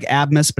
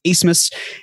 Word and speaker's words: Asmus: It looks like Abmus, but it's pronounced Asmus.